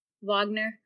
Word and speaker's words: Wagner: Wagner. I'm going to learn to pronounce that name. – Wagner. – There we go.